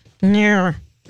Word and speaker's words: gnurr: When you're wearing, you know, pants and you get a little lint in the bottom, it's called a gnur. G-N-U-R-R. How would you pronounce that? – G-N-U-R-R. Let's say the G silent.